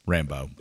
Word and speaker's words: Rambeau: I just butchered it as usual. R-A-M-B-E-A-U, Rambeau. Ramba. – Maybe Rambo. – Rambo. Okay. Anyway.